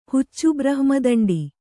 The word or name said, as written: ಹುಚ್ಚುಬ್ರಹ್ಮದಂಡಿ